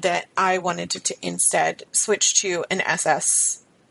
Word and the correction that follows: SNRI: Am I saying that right, Sarah? – SNRI? – Yes, and SNRI.